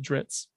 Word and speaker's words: Drizzt: but like lore wise to pronounce it Dritz.